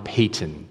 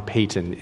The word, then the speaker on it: Paton: One of the missionaries that I think is the most influential to read is John Payton, P-A-T-O-N. The autobiography of John Payton, and that is the correct pronunciation. Love John Piper, it's John Payton.